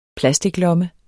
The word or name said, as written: plasticlomme